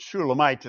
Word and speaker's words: Shulamite: The vowels, however, make us pronounce it Shulamite instead of Solomon.